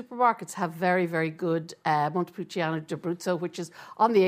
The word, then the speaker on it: Montepulciano: The best Montepulciano, I can never say that right, in Dublin. – Montepulciano.